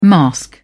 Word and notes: mask: British English

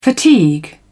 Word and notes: fatigue: British English

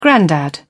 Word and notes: grandad: British English

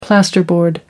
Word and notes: plasterboard: American English

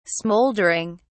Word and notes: smoldering: British English

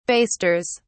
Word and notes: basters: British English

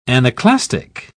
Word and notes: anaclastic: British English

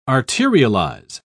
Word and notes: arterialize: British English

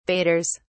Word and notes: baiters: British English

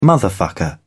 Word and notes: motherfucker: British English

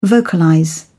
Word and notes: vocalize: British English

